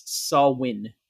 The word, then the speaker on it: Samhain: I actually wrote the pronunciation here. It's Samhain is the name.